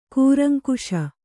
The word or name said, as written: ಕೂರಂಕುಸ